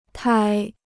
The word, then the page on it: 胎: tāi